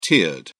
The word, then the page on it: tiered: British English